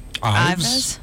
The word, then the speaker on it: Ives: But I called it Eve's Dairy instead of Ives.